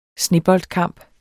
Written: sneboldkamp

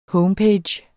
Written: homepage

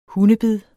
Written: hundebid

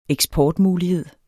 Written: eksportmulighed

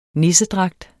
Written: nissedragt

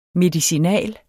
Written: medicinal

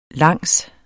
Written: langs

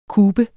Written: kube

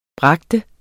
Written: bringe

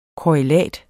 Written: korrelat

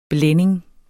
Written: blænding

